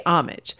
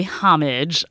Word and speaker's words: homage: And look at what I did right there. There's one pronunciation, to pay homage. Many standard dictionaries have a second pronunciation here, which is homage. No silent H going on there.